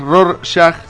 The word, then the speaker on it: Rorschach: Se me complica decirlo, tengo la pronunciación exacta, es Rorschach.